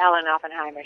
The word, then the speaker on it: Oppenheimer: I mean, Alan Oppenheimer? Is that how you pronounce it? – Yes, Alan Oppenheimer.